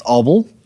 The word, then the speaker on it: Abel: In each sentence, it goes on, says, Abel, which is my name, by the way. It's just pronounced Abel because uh, I'm Afrikaans.